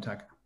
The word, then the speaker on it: Dhaka: Dhaka? – Yeah, I'm Dhaka.